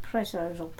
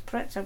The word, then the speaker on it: Prezzo: Prezzo. – Yeah. – Pronunciation is Prezzo, but everyone calls it Prezzo.